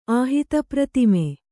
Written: ಆಹಿತಪ್ರತಿಮೆ

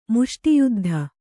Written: ಮುಷ್ಟಿಯುದ್ಧ

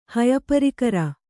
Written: ಹಯಪರಿಕರ

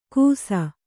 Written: ಗುಸ

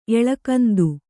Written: ಏಳಾಗಂಧ

